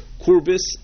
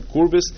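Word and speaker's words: Kürbis: The Maril and the Bach say that it means Korbil, which in the way we would pronounce it would be Korbis. Well, not with an L, but with an S at the end, Korbis, which is the German word for gourds and pumpkin, things in those family.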